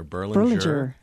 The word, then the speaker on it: Berlinger: It's actually two soft G's there, Berlinger and Bolger.